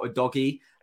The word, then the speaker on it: Udogie: I have a really good positive gut feeling about Udogie, which I'm pretty sure is the right pronunciation based on the match yesterday.